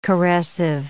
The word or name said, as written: caressive